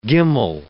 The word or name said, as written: gimel